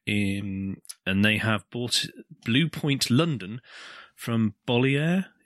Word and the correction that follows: Bolloré: Is that how you pronounce it? – Bolloré.